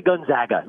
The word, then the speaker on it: Gonzaga: Just say Gonzaga, like the Zags. It's not the Zogs, it's the Zags.